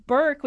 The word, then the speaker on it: Berke: And the lead is named Burke, which isn't that weird, but it's spelled B-E-R-K-E.